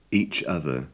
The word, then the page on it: each other: English